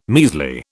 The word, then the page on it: measly: British English